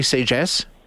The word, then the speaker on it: Sagesse: Did I say it correctly? Sagesse? – You did, yeah.